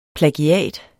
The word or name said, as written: plagiat